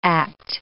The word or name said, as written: act